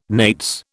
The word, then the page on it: nates: British English